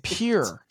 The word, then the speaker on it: Pierre: Pierre. P-E-E-R. Pierre. – P. – Is how you would say it.